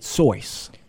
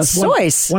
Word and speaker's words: Seuss: By the way, did you know he pronounced it Soyce? – Really? Yeah. No, I did not. Soyce.